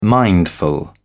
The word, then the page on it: mindful: British English